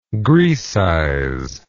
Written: graecize

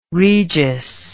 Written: regis

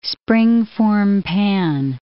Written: springform pan

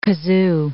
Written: kazoo

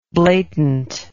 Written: blatantly

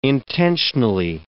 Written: intentionally

